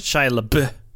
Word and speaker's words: Shia LaBeouf: I believe it's pronounced Shia LaBeouf.